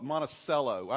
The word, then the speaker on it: Monticello: It's pronounced Monticello. I always get a laugh when I come here because everybody in the Midwest calls it Monticello.